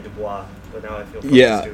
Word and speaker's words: Du Bois: I took a class and that's how it's pronounced. – That's how it's pronounced, really? – It's pronounced Du Bois, yes.